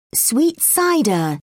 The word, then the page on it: sweet cider: British English